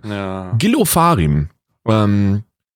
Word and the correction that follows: Ofarim: Gil Ofarim.